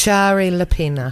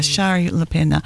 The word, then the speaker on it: Shari Lapena: And it's by, now I can never pronounce, Shari LaPena. – Yeah, Shari LaPena.